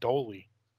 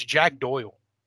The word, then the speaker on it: Doyle: What did I say? Doyle? – You said Doley. – Oh, how do you say his name? – I don't know. You're struggling today reading names. It's Jack Doyle.